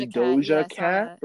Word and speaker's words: Doja Cat: I think it's pronounced Doja Cat, right? Yeah.